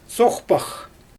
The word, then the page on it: soxpax: Xyzyl